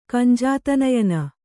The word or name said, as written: ಕಂಜಾತನಯನ